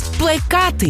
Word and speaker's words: плекати: Плекати. Wow. Що це значить? Любовно вирощувати, дбати або розвивати. Приклади. Вона плекає мрію стати зіркою. Тік-тока. Він плекає надію, що завтра буде пари. Наголос на другому складі – плекати.